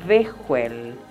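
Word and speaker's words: Behuel: Tu ángel se llama Bejuel.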